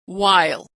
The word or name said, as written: while